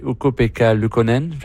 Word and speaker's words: Ukko-Pekka Luukkonen: Newhook, hier, a déjoué Ukko-Pekka Luukkonen.